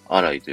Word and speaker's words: ALLY: れている時のお名前になってるんですけど、こちらの名字のライは、ライというね、単語から、えー、文字ってラ井さんというふうにね、名乗ってるらしいんですけど、このライという言葉、 ALLY と書いて、ライと読むんですけど、